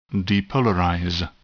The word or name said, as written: depolarize